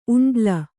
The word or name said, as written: ಉಂಡ್ಲ